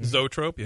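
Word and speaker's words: zoetrope: Zootrope, yeah.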